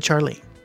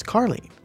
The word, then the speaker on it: Carlene: I said Charlene. – Yeah. – It's Carlene. – Oh, well, that's not how it's spelled. – Says Charlene on my paper. It's Carlene. Thank you.